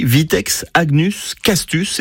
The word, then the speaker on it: Vitex agnus castus: Il était écrit Vitex agnus castus, est-ce que vous le connaissez? – Alors d'abord, vous l'avez bien prononcé, c'est pas mal.